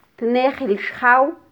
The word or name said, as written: tnejil xjaw